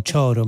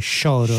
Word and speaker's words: choro: Abbiamo ascoltato la mazurka, adesso qui la pronuncia perché noi diciamo choro, ma in realtà si dovrebbe dire choro, no? In portoghese, esatto.